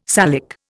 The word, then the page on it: Salic: Indian